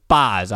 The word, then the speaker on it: Baz: Is it Baz or Baz? I cannot remember, but Baseball Reference is right in front of me, so I could always look there. The pronunciation is Baz. I was right.